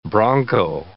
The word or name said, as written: broncho